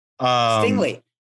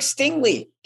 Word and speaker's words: Stingley: Then you've seen him drop out, and it's like all of a sudden it's Derek Stingley. Am I saying that wrong? – Stingley. It's Derek Stingley.